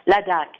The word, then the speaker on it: Ladakh: Well, talk about briefly about, you talked about Ladakh, and is that how you pronounce it? – Ladakh, yes.